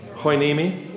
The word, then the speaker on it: Hueneme: Hueneme.